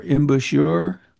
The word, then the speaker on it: embouchure: I learned a new term in your book that I didn't know before, embouchure.